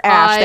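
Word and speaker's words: Æ: I think it's pronounced Ash, the A-E thingy. – E-A-L.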